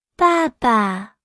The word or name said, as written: 爸爸